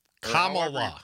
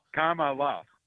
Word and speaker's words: Kamala: Kamala. – Pronounce it. I'm not trying to be disrespectful. – Say comma. – Kamala. – Kamala. There you go.